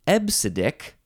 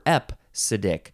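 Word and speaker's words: EBCDIC: EBCDIK, but you do pronounce it ebcidic. You can pronounce it ebcidic or epcidic. I don't know how the P sound got in there because there's no P in all those letters. So ebcidic seems like the more correct way to say this.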